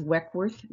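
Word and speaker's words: Weckwerth: My last name is Weckworth.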